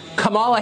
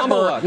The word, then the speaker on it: Kamala: You're not allowed to criticize Kamala Harris or Kamala Harris or whatever. – Kamala. – Because it's Kamala Harris.